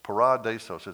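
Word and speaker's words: paradeisos: That word paradise, and those sorry Greeks put the emphasis on a wrong syllable that just makes it really hard for me to pronounce. It's paradesos. Paradesos is the second syllable.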